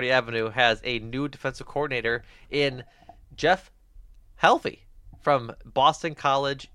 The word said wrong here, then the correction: Hafley: How do you say his last name? – I said Hafley. – Isn't it Hafley? – Hafley. Yeah, there's not a first L.